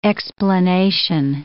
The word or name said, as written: explanation